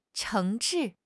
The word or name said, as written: 诚挚